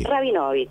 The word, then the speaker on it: Rabinowicz: Pero aunque termine W y CZ, suponemos que en polaco se pronuncia Rabinovich.